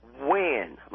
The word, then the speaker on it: Wen: I just wanted to let you know the guy in California in Orange County, that name is pronounced Wen, like W-H-E-N.